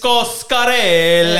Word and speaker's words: Coscarelli: Coscarelli.